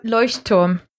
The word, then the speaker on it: Leuchtturm: Lectrum? Lectrum? – Leuchtturm. – That's the one. – Oh, very nice. I've been called so I'm from Northern Ireland, so I call it Electrum. – Yeah, the Leuchtturm 1917, is that what they're amazing.